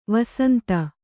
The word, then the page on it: ವಸಂತ: Kannada